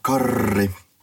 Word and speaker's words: Karri: My name happens to be Karri, or Karri, depending on your pronunciation, and my co-host is the ever reliable and awesome Henrik.